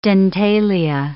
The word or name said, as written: dentalia